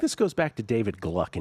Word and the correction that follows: Glick: in chess. – Glick. – Is it pronounced Glick? – Yeah.